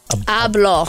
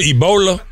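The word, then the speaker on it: Abloh: Ablo. – Say his name right. Motherfuckers right. be like Virgil Ebola.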